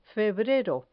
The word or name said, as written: febrero